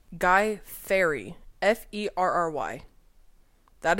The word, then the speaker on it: Fieri: That's not how you say his name. – How do you say his name? – Fieri.